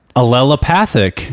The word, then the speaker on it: allelopathic: Well, interestingly enough, I'm not so surprised about this because the roots of this tree famously and really allelopathic. Did I say that right? – Allelopathic. – Yes.